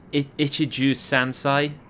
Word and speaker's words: ichiju sansai: which I'm going to try and pronounce. So it's ichiju sansai. Does that sound right? – Yeah.